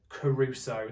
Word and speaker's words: kerusso: Now, when we look at the root word and we look at it in the Greek, it uses a word kerusso, and that's spelled K E R U S S O.